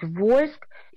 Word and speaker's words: Вольск: Потом произношение меняется на «Волжск», а потом уже смягчили, сами жители стали говорить «Вольск».